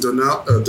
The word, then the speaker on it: D'Onofrio: Like, I would love if it was Vincent D'Onofrio. Oh, God, I'm butchering this last name. – No. Vincent D'Onofrio.